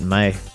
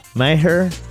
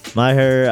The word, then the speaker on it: Maher: brett Meyer. Maher, i believe is how you say it right Maher uh,